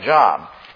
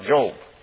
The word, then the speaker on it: Job: Now, some of you children might think it looks like Job. And it's spelled the same way. But when it's the name, you pronounce it Job. When I was a kid, I used to think it was Job. But it's Job.